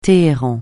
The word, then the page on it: Téhéran: francais